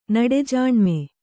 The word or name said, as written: ನುಡಿಜಾಣ್ಮೆ